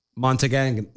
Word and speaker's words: Montagnet: It's probably French and Montagnet. – No. We're in America, and his name is Captain, so... – It's Captain Montagnet. – Montagnet. – Yeah. I said it right earlier.